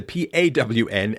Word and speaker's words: pawn: People think it's P O R N shop because I get pronunciation wrong, but it's not. It's a P A W N, a pawn, a pawn, pawn, a, a, prawn shop.